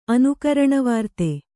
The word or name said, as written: ಅನುಕರಣವಾರ್ತೆ